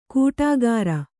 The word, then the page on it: ಕೂಟಾಗಾರ: kuṭāgāra